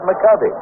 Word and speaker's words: McCovey: You know, during his entire minor league career, this fellow's name was pronounced, and he pronounced it McCovey. And he pronounced it McCovey when he came to the major leagues, but nobody else did, and he finally just gave up and said, all right, call it McCovey. So everybody does.